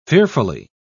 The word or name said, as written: fearfully